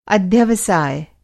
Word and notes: अध्यवसाय: Hindi

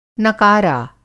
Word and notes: नकारा: Hindi

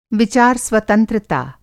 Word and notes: विचार-स्वतंत्रता: Hindi

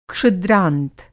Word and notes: क्षुद्रांत: Hindi

